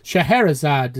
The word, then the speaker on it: Scheherazade: Yeah, this is Scheherazade, is how it's pronounced. – Yes, Scheherazade.